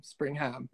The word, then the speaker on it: Springham: you're funny on spelling, because I find it hard to even pronounce my surname because I can be quite lazy in my pronunciation, but it's Springham, Springham, even if you want to spell it out like Future.